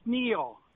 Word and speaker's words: Neal: It's Neil. – Neil, spell it. – N-E-A-L. – N-E-A-L.